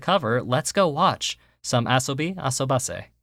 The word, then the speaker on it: Asobi Asobase: Ladies and gentlemen, we are back after watching three whole episodes of Asobi Asobase, which is the correct pronunciation after looking at the hiragana.